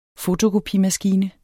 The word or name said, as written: fotokopimaskine